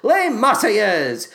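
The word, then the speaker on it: Marseillaise: Yes, that's how you pronounce it. Les Marseillaises.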